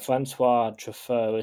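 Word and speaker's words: François Truffaut: I read something on Wikipedia that said Francois Truffaut, is that how you say his name? – Yeah.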